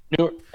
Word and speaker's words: Neuer: If I was to look at one, I don't know odds, but Germany's goalkeeper, Neuer. I might have said his last name wrong. I'm terrible at German names. – It's a Neuer.